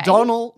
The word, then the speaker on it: Donald: Donald. I looked it up. It rhymes with tonal. That's how you pronounce his name.